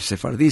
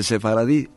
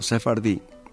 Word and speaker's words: sefardí: Es decir, sefardí se dice, no se dice sefaradí, sino sefardí, que eran aquellos judíos que habitaron España y que también hablaron una especie de dialecto judío-español.